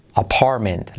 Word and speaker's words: apartment: an apartment, la T de apart, no se pronuncia, so apartment. Y la última T casi nada, apartment, ¿vale? Apartment, si quieres un poquito.